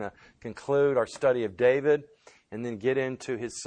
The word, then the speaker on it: David: In Hebrew, it's pronounced David. If you were to transliterate that and spell it into English, it would be D-A-V-E-E-D. But we spell it D-A-V-I-D, but it's pronounced in Hebrew David, and it literally means beloved.